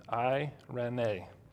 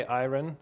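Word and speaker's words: irene (εἰρήνη): In fact, it's the Greek word irene. Irene. Some say iron. I looked at a couple, but irene is the proper Greek pronunciation.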